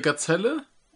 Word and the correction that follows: Giselle: Nein, danke. Giselle. Giselle, genau. Giselle. Ich bin kein guter Franzose.